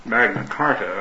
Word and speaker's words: Magna Carta: The Magna Charta, Magna Carta, I guess it's pronounced, was mainly a tax agreement between the king and the nobility.